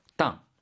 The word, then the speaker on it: 当: 땅. 발음 유의해야 되죠. 사성입니다.